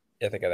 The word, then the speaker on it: Ithaca: Yeah, Ithaca.